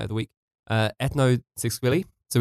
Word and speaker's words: Tsikvili: But if you just type in Tsiskvili, which there's no way you'll figure out how to spell that without me spelling it for you. So uh, T S I K V I L I, Tsiskvili.